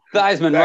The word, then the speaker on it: Theismann: Theismann rhymes with South Heisman.